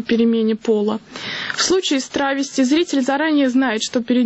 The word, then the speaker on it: травести: Поскольку речь идет о перевоплощении в женский образ, даже если молодой человек просто демонстрирует женское платье, таких артистов лучше называть «травести» с модным ударением на первый слог.